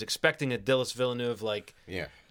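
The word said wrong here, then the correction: Denis Villeneuve: Did I say Dillis? Dillis. – That's definitely not how it was pronounced. – Dillis uh, Villajuego. – Um, no, uh, Dennis Villeneuve.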